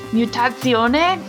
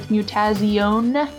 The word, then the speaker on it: Mutazione: This week we're talking about Mutazione, which the internet says is how you're supposed to pronounce this. It looks like Mutazione for you only English speakers like myself.